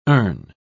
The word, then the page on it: erne: British English